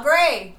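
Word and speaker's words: Au Gres: How do you pronounce A-U space G-R-E-S? – Oh, grass. – No. Oh, gray. – The why is there an S there? – It's silent.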